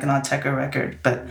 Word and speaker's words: Autechre: Am I saying Autechre right? I know some people have different ways, but you're from the UK, so how do you say it? – Autechre. And I Autechre.